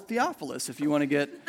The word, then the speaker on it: Theophilus: And Luke sets out to write an orderly account, as he puts it, of the gospel to the most excellent Theophilus.